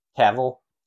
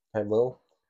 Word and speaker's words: Cavill: You know, like Henry Cavill come back, and it's Cavill. Cavill. I always want to add an extra syllable,